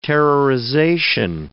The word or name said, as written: terrorization